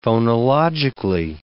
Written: phonologically

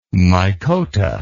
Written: mycota

